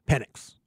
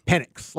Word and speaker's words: Penix: Penix. It's not like a – this is like this how you say it. Because if you say it the other way, it kind of sounds it does.